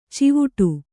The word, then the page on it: ಚಿವುಟು: Kannada